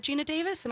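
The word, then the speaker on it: Geena Davis: Is that Gina Davis? Am I getting the name right? – Yeah.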